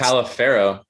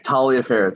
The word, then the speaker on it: Taliaferro: If my grandma hears me say anything else, it's like, well, you know, it's pronounced Taliaferro. So there's a dorm room at William and Mary, Taliaferro Hall, and that's how it's pronounced.